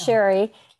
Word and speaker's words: Sherry: It's Sherry, like the wine.